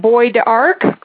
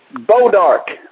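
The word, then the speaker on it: Bois d'Arc: And goes by the name, hopefully I pronounced this right, is it Boy D'Arc? – Bodark. – Bodark, okay, that sounds more like it. – like B-O-W and then Dark, but it's sp- It's spelled. It's a French word. B-O-I-S, apostrophe D, apostrophe A-R-C.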